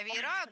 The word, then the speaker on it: веера: А веера, тут нету даже вариантов. Только на «а» ставится. – Итак, действительно, слово «веер» имеет ударение на корне в единственном числе и ударение на окончании во множественном.